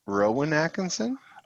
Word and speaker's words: Rowan Atkinson: It's Ron Atkinson. – I don't know who Atkinson is. Who's that? – Am I saying his name wrong? Rowan Atkinson? – Oh, Rowan Atkinson.